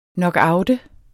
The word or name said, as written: knockout